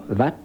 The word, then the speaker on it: Wat: Euh, le plus connu, c'est encore Vat ou Wat, mhm. selon la façon dont on prononce, euh, qui a été le plus conservé et qui reste le, plus beau.